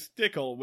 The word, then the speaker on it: Stickle: George's last name is Stickle, which rhymes with pickle.